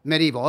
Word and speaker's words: medieval: And I don't know, is it medieval or medieval? Four syllables or three? You tell us. – It's pronounced usually with three. It means Middle Ages. – Okay. – Medieval, okay.